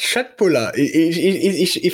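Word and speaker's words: Köttbullar: Aussprache ist Schöttbuller.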